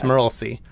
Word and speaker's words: Merlsi: His name was Merlsi. That is Murral. S-E-E, one word. – I read it, and I was like? – It's Merlsi. And his mom snuck him to the cave of...